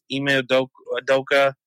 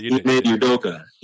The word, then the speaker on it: Ime Udoka: And secondly, the reason why I'm like this is, like, if you're really behind Ime Udoka, which am I saying the name right? – Yeah, you did. – Ime Udoka.